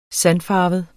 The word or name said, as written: sandfarvet